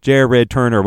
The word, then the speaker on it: Jared Turner: And my name is Jared Turner. It is Jared Turner. We got 4 syllables in there.